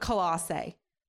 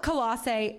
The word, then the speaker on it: Colossae: I call it Colossae. What? Yeah, C-O-L-O-S-S. Some of your translations will have A-E. Some of your translations will have E. So I call it Colossae.